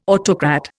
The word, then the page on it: autocrat: Indian